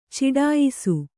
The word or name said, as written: ಚಡಾಯಿಸು